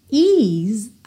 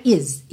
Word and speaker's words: ease: E atenção à pronúncia dessa palavra ease. Percebe como esse começo dela é alongadinho. Ease, não é is, is, é ease, ease, é barrigudinho, ease ok?